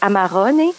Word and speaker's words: Amarone: On dit Amarone, mais les Italiens prononcent le E comme un E, donc Amarone.